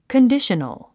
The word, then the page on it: conditional: kəndíʃənl; カンディショナル